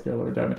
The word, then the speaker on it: Scleroderma: They had a young little Harrison there, and they were promoting that it was World Scala Radona. Sorry, Day. I've definitely mispronounced that. Scleroderma Day, World Scleroderma Day.